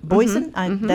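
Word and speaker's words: Boisen: Boissin. – That's the way it's pronounced.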